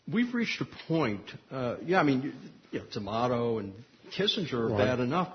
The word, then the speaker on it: D'Amato: People say D'Amato rhymes with tomato, but I'm from Kansas City. I can't bring myself to say tomato. But it does happen to rhyme with sleazeball obligato.